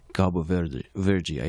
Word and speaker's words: Cabo Verde: Cabo Verde, I think is how it's pronounced.